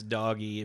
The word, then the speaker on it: Doge: That's doggy if you don't know how to pronounce it right.